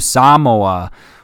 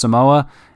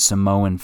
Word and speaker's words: Samoa: And it's like, bum, bum, bum, time to go to Samoa, which I thought was really cool, actually. I thought it was pronounced Samoa, and it isn't. I guess it's pronounced Samoa, which is cooler, obviously.